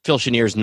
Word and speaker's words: Chenier: how's that pronounced, Phil Chenier? – Phil Chenier, yep. – Chenier, okay.